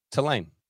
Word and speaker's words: Tulane: Tulane.